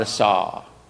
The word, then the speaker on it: asah: And that word is spelled A-S-A-H, asah.